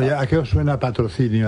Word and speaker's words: Patrocinio: Eduardo lo ha pronunciado patrocinio. – Perfecto.